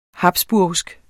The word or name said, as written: habsburgsk